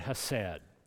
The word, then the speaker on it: hesed: And it is, if I pronounce it right, it is the word hesed, H-E-S-E-D, sometimes spelled C-H-E-S-E-D.